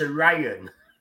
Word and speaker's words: Orion: So just say it properly, it's Orion.